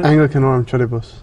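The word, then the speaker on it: Anglicanorum Coetibus: Anglicanorum Charibus.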